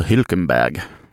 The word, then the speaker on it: Hülkenberg: var ju Nico Hülkenberg, eller Hülkenberg som man ska uttala. Jag såg att du rynkade på näsan där till mitt ursprungliga uttal.